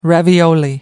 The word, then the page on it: ravioli: American English